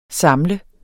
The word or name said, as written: samle